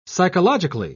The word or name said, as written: psychologically